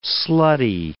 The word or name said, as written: slutty